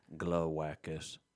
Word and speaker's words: Glawackus: You said you were a lone wolf, but there's more Glawackuses? – Well, I don't want to correct the host, but adding an extra syllable to my name. – Glawackus? – Glawackus.